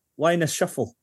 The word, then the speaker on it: Wyness Shuffle: Wyness Shuffle? – You did, aye.